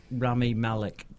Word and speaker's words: Rami Malek: And then suddenly Rami Alec, he's like, oh, I don't think this is going to work out. – I don't think that's how he pronounces it. – Rami Malek. – That's it.